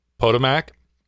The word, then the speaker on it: Potomac: Potomac? – Yep.